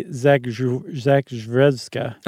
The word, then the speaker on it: Zakrzewska: She got her degree at Western Reserve University in Cleveland, and she joined her in 1856 with another doctor, Dr. Marie Zakzuska. Wow. – That's the Dr. Seuss pronunciation. – That's a tough one. – I would say Zakrzewska.